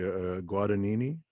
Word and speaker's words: Guadagnini: I read that you play, and I hope I'm pronouncing this correctly, Guadagnini? – Yes, you are pronouncing it perfectly.